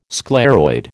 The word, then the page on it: scleroid: American English